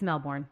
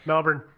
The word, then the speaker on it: Melbourne: Yeah, say it right or we're going to get angry emails. – Mm. Yeah, come on. It's Melbourne. Calm down. – Melbourne.